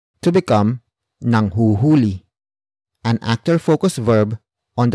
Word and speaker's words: nanghuhuli: For example, nanghuhuli sila ng kulisap.